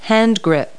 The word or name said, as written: handgrip